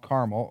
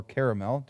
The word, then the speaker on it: Carmel: There's these caves on Mount Carmel or Caramel, depending on how you prefer to pronounce it.